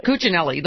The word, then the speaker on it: Cuccinelli: Cuccinelli, the one who obviously, you know, brought this case in the first place.